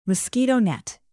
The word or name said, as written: mosquito net